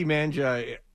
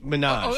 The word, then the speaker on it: Minaj: Minaj. – Oh, okay. – Manja is what I called her. – Okay. – Nicki Minaj.